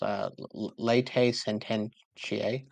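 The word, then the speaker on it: latae sententiae: So it's pronounced laete sententiae, I believe.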